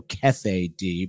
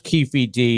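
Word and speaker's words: Keffe D: It's spelled different. The AP spells it with two F's and one E, so Kefe D, but it's pronounced Keefe D.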